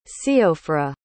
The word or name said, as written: síofra